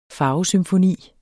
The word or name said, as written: farvesymfoni